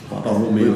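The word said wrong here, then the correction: Borromäus: Ich kann es nur schlecht aussprechen, Baromeus, genau. – Boromeus.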